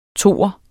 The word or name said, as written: toer